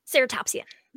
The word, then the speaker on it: ceratopsian: Ceratopsian.